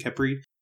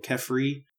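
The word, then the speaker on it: Khepri: They're still all pretty much pronounced Kepri or Kefri.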